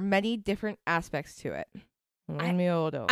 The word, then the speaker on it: Onmyōdō: Onmyodo. – Yeah. It's spelled O-N-M-Y, O with like a line above it. – Okay. – D-O with a line above it. – Okay. – Onmyodo.